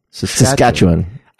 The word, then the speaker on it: Saskatchewan: How do you say it? – The way you said it. – Oh, nice. – Saskatchewan.